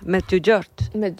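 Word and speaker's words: Međugorje: Medjugorje.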